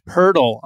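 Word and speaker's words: Pöltl: And have not liked many of the variants, but then they dropped Purtle on us, which is how you pronounce the NBA player who Ja Morant like put into a grave dunking on a few weeks back.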